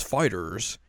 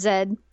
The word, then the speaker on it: FighterZ: Even though Bandai Namco is like, you pronounce that as FighterZ. And it's like, you motherfucker put a giant Z on the title of your game. – Just call it Zed.